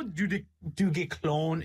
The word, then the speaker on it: Duguesclin: I promise you I am saying that correctly. It's just taking all of my effort to... Do you want to know how du Guesclin is spelt, man? D-U-G-U-E-S-C-L-I-N. Duguesclin.